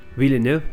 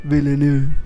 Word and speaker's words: Villeneuve: Villeneuve. – Villeneuve.